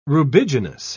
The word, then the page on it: rubiginous: British English